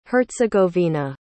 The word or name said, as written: herzegovina